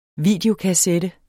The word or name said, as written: videokassette